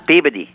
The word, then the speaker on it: Peabody: And I did say it right, Peabody, right? It's not Peabody.